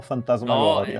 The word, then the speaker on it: fantasmagoria: Dove va l'accento? – Fantasmagoria o fantasmagoria? – No, è fantasmagoria.